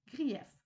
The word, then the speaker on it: grief: Grief, nom masculin prononcé grief, veut dire grievance en anglais.